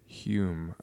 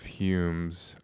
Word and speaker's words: Hume: I think what I like so much about John Ciardi is, funnily enough, something I actually like about David Hume, who I've heard actually is supposed to be pronounced like something like home or whom, maybe with more of a Scottish whom. Hume, I don't know.